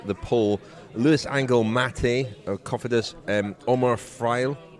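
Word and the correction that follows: Fraile: is that how you pronounce his name? – Freile. – Omar Freile, the Dimension data rider, who's been in a lot of breaks this week.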